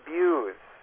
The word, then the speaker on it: Bews: Dr. Jim Buse. Jim Buse. And again, if you go on YouTube, J-I-M, and the last name is spelled B-E-W-S.